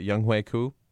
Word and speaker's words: Younghoe Koo: I maybe not going to get this pronunciation correct, but Younghoe Koo.